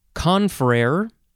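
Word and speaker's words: confrère: You can pronounce this a few ways. First of all, you can emphasize either syllable, confrere or confrere.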